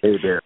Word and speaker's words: Hebert: And I was going to read bios first, but I think what we'll do first is we're waiting for the playwright, Julie, how do you pronounce Julie's last name? Is it Hebert? – Hebert. – Hey Bear. – Yeah. – Hey Bear. – Like Hey Bear.